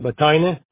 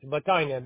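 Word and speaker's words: Batayneh: My email is Batina, which is the same as my last name.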